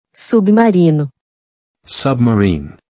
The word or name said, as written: Submarino